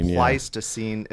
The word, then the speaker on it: Pleistocene: Pleistocene.